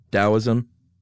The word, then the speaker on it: Taoism: do we say it with a D, Taoism? – Yeah. – Okay, that's what I thought, even though it's spelled with a T.